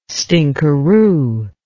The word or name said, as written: stinkaroo